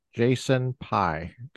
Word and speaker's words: Jason Pye: Today we have with us Jason Pye.